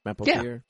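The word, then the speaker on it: Mapother: Is that how you say his name? Do you know? Mapothier? – Yeah, okay.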